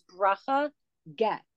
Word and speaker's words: Bracha Getz: Her name is, it's a little hard to pronounce because it's a Hebrew name, but it's Bracha Getz.